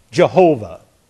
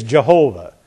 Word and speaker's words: Jehovah: The proper way is Jehovah.